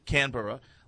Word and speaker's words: Canberra: Canberra last week.